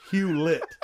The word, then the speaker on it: Hewlett: David Hewitt. I didn't even pronounce it right. It's Hewlett. Jeez.